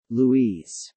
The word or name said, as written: Louise